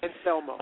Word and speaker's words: Anselmo: Anselmo. – Okay. – Anselmo.